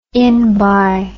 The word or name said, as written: inbye